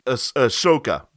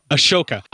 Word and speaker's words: Ahsoka: I'm kind of still hoping that, is it Ahsoka is how her name is pronounced? I never pronounce it right either. – Ahsoka. – Ahsoka.